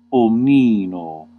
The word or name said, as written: wholly